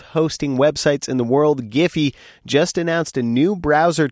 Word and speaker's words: Giphy: We say Giphy. – You say Giphy? – Hard G.